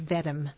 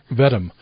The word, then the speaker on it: Vedem: Is that how you pronounce it? – It's Vedem. – Vedem.